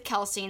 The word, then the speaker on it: Kelsi: Super Kicking It with Kelsey, and that's spelled SuperKickingIt with Kelsey. My name is spelled KELSI.